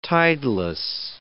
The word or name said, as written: tideless